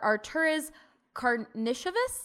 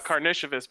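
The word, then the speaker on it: Artūras Karnišovas: The Denver Nuggets general manager, Arturas Karnishevas? – Hey.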